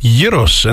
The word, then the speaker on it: Yiros: Yeesh, I just tell people it's pronounced Yiros and they give me a funny look.